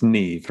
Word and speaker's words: Niamh: Uh, I think it's Neve. It's the Irish spelling N I A M H. – That's pronounced Neve, right?